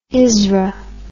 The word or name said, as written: isra